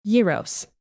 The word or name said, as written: yiros